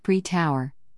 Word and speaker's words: pre-tour: pre-tour, one heavily doctored cup of joe in hand, I head off to the pre tour, pronounced pre tower, safety meeting.